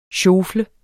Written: SJOFLEDES

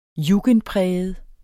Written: jugendpræget

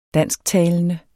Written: dansktalende

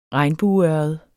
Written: regnbueørred

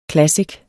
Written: classic

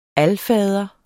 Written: alfader

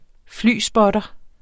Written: flyspotter

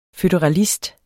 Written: føderalist

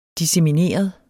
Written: dissemineret

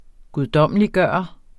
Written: guddommeliggøre

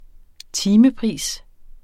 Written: timepris

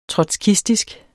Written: trotskistisk

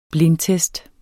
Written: blindtest

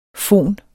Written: fon

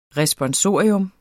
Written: RESPONSORIUM

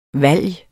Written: VALGET

